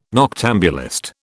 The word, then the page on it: noctambulist: British English